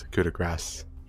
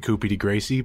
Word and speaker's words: coup de grâce: It's coup de grâce, French word. – Well, I'm looking at the pronunciation here. It says coup de grâce with an A-H-S. – I mean, I like pronouncing it Coupie de Gracie, but I'm Coupie just acknowledging when it's wrong.